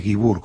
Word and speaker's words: Guibur: Mi apellido es Guibur, con acento en la U. – Ah, bien. – Guibur.